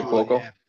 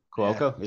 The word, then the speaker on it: Cuoco: The other person I thought would have been Kaylee Cuoco, I think is how you pronounce her name. – Kaylee Cuoco. – Cuoco, is it? Yeah, Cuoco, from Big Bang Theory, is obviously the where her big thing is.